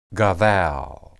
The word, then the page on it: gadal: hebrew